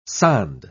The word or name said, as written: sonde